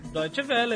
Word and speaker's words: Deutsche Welle: Deutsche Welle, é isso.